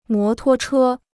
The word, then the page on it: 摩托车: mó tuō chē